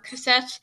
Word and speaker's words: Cosette: Pronunciation here, guys. – It's French. – Yeah, Cosette.